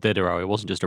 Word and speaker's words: Diderot: Well, you don't pronounce the T. That's usually a sign. – Diderot.